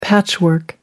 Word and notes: patchwork: American English

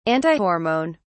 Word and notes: antihormone: British English

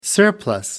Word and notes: surplus: American English

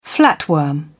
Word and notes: flatworm: British English

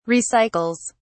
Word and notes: recycles: British English

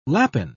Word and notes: lapin: British English

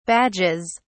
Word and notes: badges: British English

